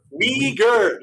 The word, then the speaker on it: Uyghur: Uyghur, Weeble, am I saying it right? – Uyghur. – Uyghur, yes. I screw everything up.